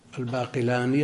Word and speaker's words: الباقلاني: القاضي أبو بكر الباقلاني يضبطونه بالتخفيف بخلاف الغزالي بالتشديد، وهذا على خلاف المشهور، الناس ينطقونه بالعكس.